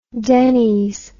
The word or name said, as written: dennys